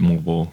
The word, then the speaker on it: Moalboal: Okay, so the pronunciation I got off the internet is Moal Boal, but you're calling it Moal Boal. So it's whatever you want to call it. Or what? How do the locals say it? Moal Boal. – Yeah, the locals call it Moal Boal.